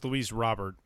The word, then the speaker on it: Luis Robert: And it is pronounced Luis Robert. We were wondering throughout the signing period if it was Luis Robert or something like that, but it is Robert.